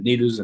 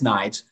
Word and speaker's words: Nides: I'll take no credit for that because his name is spelled N-I-D-E-S. Everyone said this is Nides and Nidus and they can't get it right. It's Knights.